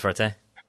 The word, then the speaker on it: Partey: I'm looking at, you know, the fact that they just signed, was that Partey, is that how you say his name? – Yeah, Thomas Partey.